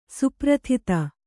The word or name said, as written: ಸುಪ್ರಥಿತ